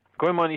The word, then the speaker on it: Goemon: Was it Goemon? Is that how you say it correctly? – Yeah, yeah. Goemon Ishikawa.